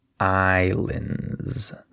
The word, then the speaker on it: islands: Notice that the S is silent. So we say islands. There's no S sound.